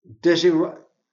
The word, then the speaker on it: Desiderata: Desiderata. Desiderata. – Desiderata. – Desiderata. D-E-S-I-D-E-R-A-T-A.